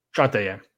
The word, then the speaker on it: Shantae: Is it Shantae? – Shantae, yeah.